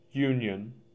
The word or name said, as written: Union